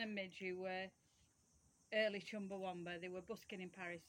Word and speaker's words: Chumbawamba: How do you pronounce it? – Chumbawamba. – Well, everybody says Chumbawamba because we're... because we've got Lancashire accents.